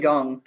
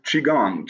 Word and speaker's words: Qigong: And then something, I'm not sure if I'm going to pronounce this right, Qigong? Am I saying that right? – Qigong. – Qigong.